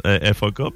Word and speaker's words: faque: FAK, mais techniquement, ça se prononce FAK, mais ça s'écrit surtout le FAQUE.